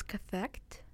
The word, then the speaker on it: cathect: So we figured out how to pronounce that word in the Nice Apples album. – Yeah, so it's cathect.